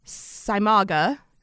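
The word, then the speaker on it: Seimaage: I wrote down the pronunciation for this. Saimaga. They stuff a pig's stomach with potatoes, sausage, cabbage, and onions.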